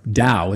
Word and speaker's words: Tao: And the Tao, or Tao as it's properly pronounced, is a Chinese word that means the path or a way of being.